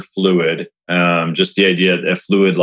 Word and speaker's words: Phluid: So I want to make sure that the Fluid Project is spelled P-H-L-U-I-D, Fluid.